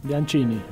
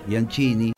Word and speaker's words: Bianchini: Eh, junto a Mariana Bianchini, Bianchini, ¿cómo se dice? Bianchini. Bianchini, que es una cantante muy personal.